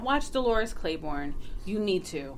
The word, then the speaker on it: Claiborne: And the zingers, it's Kathy Bates plays Dolores Claiborne.